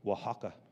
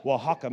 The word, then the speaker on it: Oaxaca: Got a great friend, got a great friend here from Oaxaca. Did I say it right? – Yeah. – yeah I, I did? Yeah. Oh man, I said it right. Oaxaca, Mexico.